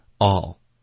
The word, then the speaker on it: I'll: 它的这个缩写，的发音标准的发音是 I'll。 Okay, it's very hard. It's very difficult. 我们可以把它弱读。 Okay, 很常见的一个弱读就是把它发成 all。就是 A-L-L 这个发音一模一样，所有 all 的这个发音 it's the same。